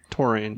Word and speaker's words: taurine: Well, CoQ10 is really important for the energy in the heart, but taurine really helps. – Taurine? – Taurine. – Yeah, it's spelled T-A-U-R-I-N-E.